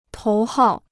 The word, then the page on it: 头号: tóu hào